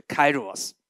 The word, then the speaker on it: Kairos: 所以记得今年对我们来说，那个最夯的字就是 Kairos。大家说一次 Kairos。 OK，这是英文发音。好，呃，我们知道这是一个神所要赐给我们的时间点，在希伯来文就是 at 时间跟 karach 机会。 OK，但是 kairos 包含了这两个，所以我们称为时机之年。